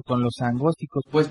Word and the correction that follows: agnósticos: Agnósticos. Agnósticos. Sí. dijiste angósticos. – Dije angósticos. Agnósticos, perdón.